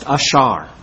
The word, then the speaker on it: ashar: And it's a Hebrew word pronounced ashar. And ashar simply means happy.